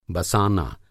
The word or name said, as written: बसाना